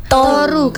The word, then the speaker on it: Toru: Gimana bacanya? – Toru, Kak. – Toru. – Karena mengambil dari bahasa Jawa, turu.